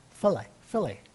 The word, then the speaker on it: philia: Then we got the philia, P-H-I-L-I-A, pronounced F-I-L-L, dash E-E, dash U-H, capital F-I-L-L, but philia, is the type of intimate love in the Bible that most Christians practice toward each other.